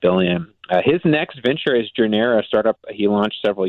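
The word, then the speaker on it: Journera: Is that Jornera? – That's right, and you are certainly not the only one who has trouble announcing pronouncing startup names. Yeah, this company is called Jornera, a Chicago startup actually founded by the founder of Orbitz, Jeffrey Katz.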